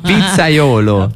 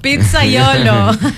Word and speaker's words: pizzaiolo: Pizzaiolo. – Pizzaiolo.